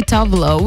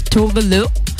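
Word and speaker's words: Tove Lo: And yes, if you guys thinking, oh no, you read her name wrong, it's actually Tavlo. Well, no, it's not. She's Swedish and she said in an interview that her name is actually pronounced as Tovelo.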